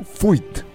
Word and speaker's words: fuit: Pero lo pronunciaste mal, es Fuit. O sea, Neru Fuit. – Fuit. Porque era el ruido que sí, es una cosa. Es una onomatopeya, el Fuit.